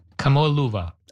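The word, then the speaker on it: Kamoa-Luva (Kamoʻoalewa): The space rock, is it Kamoa-Luva? How do you say it? – Yeah. Kamoa-Luva.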